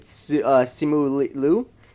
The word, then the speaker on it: Simu Liu: I mean, it's also like we didn't talk about it with Shang-Chi, but you know the guy, what is it, Simu Liu?